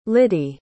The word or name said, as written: liddie